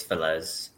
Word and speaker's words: Feliz: In a Spanish way, it's Feliz. – Feliz. All right. – Feliz. – So we ended up losing Michael Feliz on waivers to the Athletics.